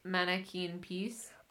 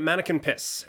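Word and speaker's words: Manneken Pis: Do you think it's pronounced Mannequin Piece? – as if I didn't Google this to make sure I was pronouncing it right.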